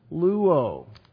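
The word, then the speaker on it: luo (λούω): Jesus said to him, "He who has luo," different word, L-O-U-O, L-O-U, long O.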